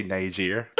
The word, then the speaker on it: Niger: Nigel. – It's actually Niger.